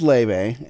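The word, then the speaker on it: Laufey: And so we were like, we want to get Kenzie tickets to her favorite artist, which right now is an artist named Leve. Although it's spelled Lofi. Let's just point that out. – Anyway. But it's pronounced Leve. – Yes. So it's Leve, and we were trying to get tickets, but she has not announced her California tour yet, just everywhere else.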